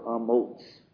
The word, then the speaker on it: Amoz: This is English AMOZ, and this is AMOS. We don't make much distinction the way we pronounce an Aleph or a Sadi and a Sami.